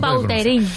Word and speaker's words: Vautrin: La original francesa, Vautrin. – Ahí pega un Vautrin.